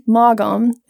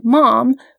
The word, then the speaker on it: Maugham: I did not spend any time trying to figure out how to pronounce his last name, which I pronounce phonetically, Mogom. It is actually pronounced Mom, which I should have known because there is a famed British author, Somerset Maugham.